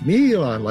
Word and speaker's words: Milan: So the name of the piece is Milan, like M-E-E-E-L-A-A-N. So that's sort of an exuberant pronunciation of Milan's name.